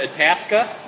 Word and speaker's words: Itasca: Church Street Brewing Company in Itasca, Brewing Illinois. Company. – How do you pronounce that? Itasca? – Yes.